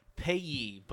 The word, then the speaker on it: Piax: Payee, but it doesn't matter. – P-I-A-X.